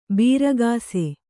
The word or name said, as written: ಬೀರಗಾಸೆ